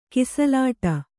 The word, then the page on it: ಕಿಸಲಾಟ: Kannada